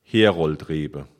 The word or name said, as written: Heroldrebe